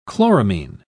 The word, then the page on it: chloramine: British English